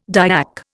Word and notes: Dyak: Indian

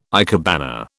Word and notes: ikebana: British English

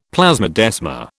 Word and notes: plasmodesma: British English

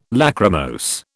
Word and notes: Lachrymose: British English

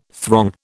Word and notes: throng: British English

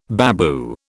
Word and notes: babu: British English

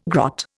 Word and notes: grot: Indian

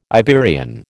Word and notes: Iberian: American English

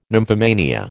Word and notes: nymphomania: American English

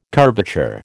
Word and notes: curvature: American English

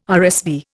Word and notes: RSV: Indian

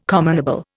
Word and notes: commonable: Indian

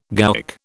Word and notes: gowk: British English

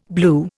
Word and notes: blew: Indian

